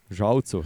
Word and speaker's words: Žalcu: Uh, štartali smo torej v žalcu, uh, enako kot lani, Ža, žalcu, žalcu. – To, to sem danes bil uh, uh, pod na novo poučen, oziroma so me podkovali v pravilni izgovorjavi.